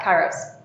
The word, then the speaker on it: kairos: Am I pronouncing it right? – Yeah, kairos. Yeah.